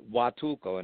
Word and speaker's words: Huatulco: It's pronounced Huatulco, and it sounds like Watulco, but it's actually Huatulco, and it's about almost 12 hours directly south of Acapulco on the southern coast of Florida.